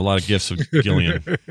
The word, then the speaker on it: Gillian: Hey, Gillian Jacobs pronounces it with a hard G from Community.